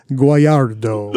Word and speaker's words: Guagliardo: Guagliardo.